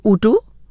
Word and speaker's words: UTO: अच्छा कोड मैंने शेयर कर दिया, चलो इट्स प्रोनाउंसड ऊ टू sorry my bad 3631 my apologies, इसलिए मैंने पहले ही कह दिया था कि शायद मैं गलत प्रोनाउंस कर रही हूँ. ऊ टू, I hope I said it right, but spelling इसकी UTO है ना, तो मैं थोड़ा सा कन्फ्यूज हो गई थी, UTO है मगर हम ऊटू बोलेंगे तो लोग इसको WOTO लिखेंगे, लेकिन कन्फ्यूजन मुझे हो गया था.